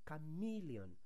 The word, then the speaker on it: chameleon: Oftentimes you hear people say chameleon. That's a lizard that can make its skin the color of the things around it. But the correct pronunciation for that word is chameleon, not chameleon. The E in chameleon is sounding E, not A.